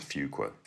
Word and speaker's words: Fuqua: And so they often tell us, make sure you say Fuqua. – Yeah. – So, oh my God, that's so funny. – Yeah, yeah. – All right, well, you got me. – Well, now they listen to this podcast, they know how to pronounce Fuqua. Few qualify.